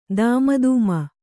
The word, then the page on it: ದೂಮುಧಾಮು: dūmu dhāmu